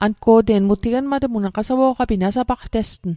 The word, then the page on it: nasapaqtesg: Mi'gmaq